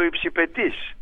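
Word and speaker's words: υψιπετής: Υπάρχει ο υψηπέτης, υπάρχει και ο υψηπετής. Ένας τόνος τα χωρίζει. Ο υψηπέτης είναι από το ύπταμε, ο υψηπέτης αετός, και ο υψηπετής που τονίζεται στη λίγους είναι αυτός που πίπτει.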